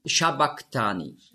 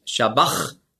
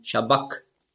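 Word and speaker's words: sabachthani: Und das CH im Griechischen, das ist ja ein Buchstabe, das Chi, das hat man ausgesprochen so wie ein C hinten im Gaumen. Also nicht Shabach, sondern Shabak.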